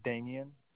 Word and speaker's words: Damien: Yeah, my name's Damien, D-A-M-I-E-N.